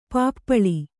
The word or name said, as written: ಪಿಪ್ಪಾಲ